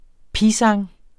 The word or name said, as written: pisang